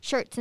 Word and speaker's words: Schertz: It's called Schertz.